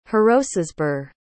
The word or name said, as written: hrocesburh